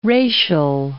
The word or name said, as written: racial